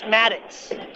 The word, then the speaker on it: Maddox: My name is Maddox. I'm a famous author. You can Maddox? – Google me. Yes, Maddox. – How do you spell that? – M-A-D-D-O-X.